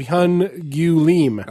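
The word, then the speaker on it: Hyun Gyu Lim: Of Hun Gyu Lim. – Oh. – Was that close? – Yeah.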